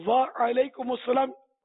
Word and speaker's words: وعلیکم السلام: وعلیکم السلام, وعلیکم السلام یہ رٹنا پڑے گا, ورنہ نکلا ہے وعلیکم, یعنی جو تھوڑا تلفظ میں سمجھتا ہوتا ہے مخارج میں تو وعلیکم یہ بھی غلط ہو گیا نا, یہ پہاڑی بکرا کیس خراب ہو گیا نا, اس لیے وہ سیکھنا پڑے گا.